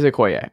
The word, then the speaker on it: Okoye: Yeah, it's Okoye. – It is Okoye.